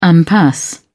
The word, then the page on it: impasse: British English